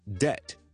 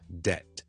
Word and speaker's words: debt: 另外一个我们很常讲讲到 silent letter 的字也是一个 silent b，就是 debt debt debt 一个前债咧，debt， so 我哋通常咧，我哋唔会读 debt，ok 唔系 debt，嗰边系完全唔需要理嘅，就咁读，可以当系 debt 咁啊，debt， like I'm indebted to you，我系欠咗你好多，I'm indebted to you， I'm deeply indebted to you。